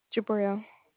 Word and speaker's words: Jabrail: Jabril. – Oh, boy. How do you spell it? – J-A-B-R-A-I-L.